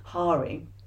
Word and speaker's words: Hari: he pronounces it, Hari, where he's saying that the people at Instagram created filters for a laugh, you know, but it's now become...